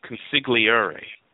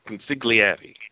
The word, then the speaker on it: consigliere: I'm sorry, one of my great friends often referred to the process, as a consigliere. – Yeah. – How do you pronounce that word? – Consigliere. – Consigliere.